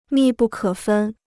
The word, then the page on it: 密不可分: mì bù kě fēn